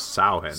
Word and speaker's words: Samhain: but Conal Cochran, who's played by Dan O'Herlihy in Halloween 3, pronounces what I believe is the correct Celtic way, is which is Samhain.